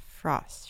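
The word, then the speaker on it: Fröst: I don't really know how to say his name properly. He's a Swedish, right? – Frost. – It's O with those two. Does it have the dots over it? – Yes.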